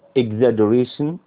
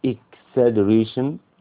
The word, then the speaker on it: exaggeration: exaggeration بص خلي بالك جي جي ورا بعض دولت هيتنطقوا little d فانت بتقول exaggeration او exaggeration برضو ما بتجيبش دول جي دول little d مع بعضهم جنب بعض كده